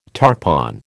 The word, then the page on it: tarpon: American English